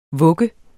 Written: VUGGENS

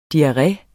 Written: diarré

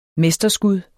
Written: mesterskud

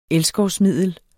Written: elskovsmiddel

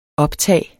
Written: optag

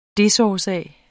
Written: desårsag

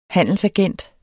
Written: handelsagent

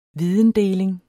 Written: videndeling

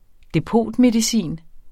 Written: depotmedicin

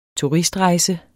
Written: TURISTREJSE

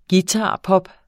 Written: guitarpop

